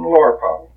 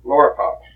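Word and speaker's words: Loerpazel: Will you give your name, Well, it's William Harrison Lorpow. L, that's spelled L-O-E-R, P as in Paul, A, Z as in boy, E, L, Lorpow.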